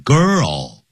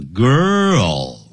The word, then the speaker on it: girl: 叫 girl，女孩子不要念 go，叫 girl，啊，舌尖翘起来，像那 O，像欧洲的 O，舌尖翘起来。